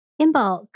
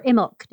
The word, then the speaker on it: Imbolc: Imbolc or Imbolc, depending on who you ask.